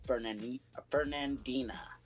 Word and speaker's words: Fernandina: It's about a giant tortoise believed to be extinct for over 100 years, has been discovered on the Galapagos Island of Fernandina.